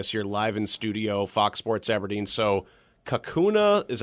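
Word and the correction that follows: Kaukauna: am I pronouncing that right? – Kakana. – Kakana, Wisconsin. Okay, gotcha.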